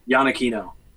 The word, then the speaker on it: Iannacchino: How do you say your last name? – Iannacchino. – There you go. I was way off. – What were you going to say? – Iannacchino. I think I had an Ian at the front.